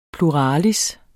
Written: PLURALISSERNES

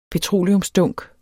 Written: petroleumsdunk

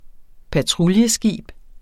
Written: patruljeskib

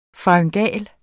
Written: faryngal

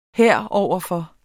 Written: heroverfor

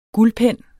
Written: guldpen

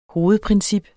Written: hovedprincip

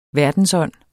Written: verdensånd

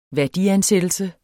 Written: værdiansættelse

